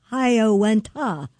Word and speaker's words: Hiawatha: This is spelled H-O-Y-O, went ha. – Yeah. – It's Hiawatha. – Yeah. Correct.